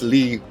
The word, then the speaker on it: Leigh: I normally don't pronounce my middle name just because people aren't interested, but yeah, it's Lee, spelled L-E-I-G-H.